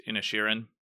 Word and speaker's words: Inisherin: And my top one was Banshees of, apparently it's pronounced Inisherin.